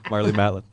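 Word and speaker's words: Marlee Matlin: Oh, the Cocker would be Marley Matten. Did I get the name right? – Yeah, Marley Matlin.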